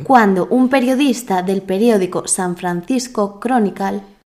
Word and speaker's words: San Francisco Chronicle: Se dice San Francisco Chronicle.